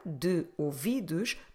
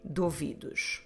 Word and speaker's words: de ouvidos: Instead of saying de ouvidos, that sounds a bit artificial, what we try to do is that we run those two together and we say duvidos. There's no apostrophe or anything in Portuguese to do that, it's just a normal way to pronounce it. So you could split them, de ouvidos, but that doesn't sound very natural. You can run them together, dovidos.